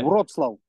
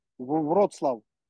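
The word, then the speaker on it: Wrocław: And uh, for people who, who might not be familiar with, sorry, I don't want to get the, the pronunciation of the city wrong. Can you can you uh, say Wroclaw? Wroclaw.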